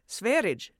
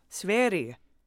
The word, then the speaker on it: Sverige: So I tried again with Sverige Demokraterna, and now you've all come back saying no, no, no, soft G like a Y sound.